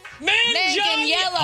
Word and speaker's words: Manganiello: Manganiello.